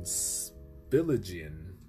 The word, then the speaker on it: Spillage Village: And then Spilligion, that's how you say it.